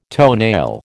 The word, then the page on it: toenail: American English